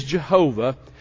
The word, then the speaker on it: Jehovah: Now we know that that's not the correct pronunciation, but that's the widely accepted. His name is Jehovah.